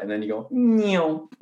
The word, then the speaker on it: Nyon: It's called Nyon. That's how you say it in French. N-Y-O-N.